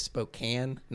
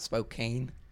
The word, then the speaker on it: Spokane: I just like point out it's Spokane, not Spokane.